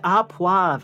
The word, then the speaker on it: au poivre: So steak a poivre. I don't even know how do you pronounce that? P-O-I-V-R-E. – A poivre.